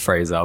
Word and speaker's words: Fraser: For the listeners, it's actually pronounced Fraser, but we'll let it go.